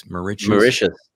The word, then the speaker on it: Mauritius: I would love to grab Tammy and come down to, how do you say it? Mauritius? – Mauritius. Yeah. – Mauritius. It's you like should. Delicious. That's what I need to remember. – It is. It's like delicious.